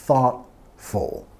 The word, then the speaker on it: thoughtful: Y vamos a enfatizar la primera sílaba. Thought, thoughtful, thoughtful.